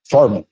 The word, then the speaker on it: Furman: Yes, my name's Fred Furman. I'm at 112 South Nashville Avenue. – Can you say your last name again? – Furman, F-U-R-M-A-N.